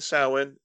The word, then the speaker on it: Samhain: And Samhain. – And Samhain, if you're a druid.